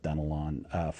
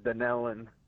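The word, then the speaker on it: Dunnellon: I'm going to go with Dunelon, Florida, watching on YouTube. I hope I said the town right. I have not heard of that town before. – No, actually, it's pronounced Dunelon.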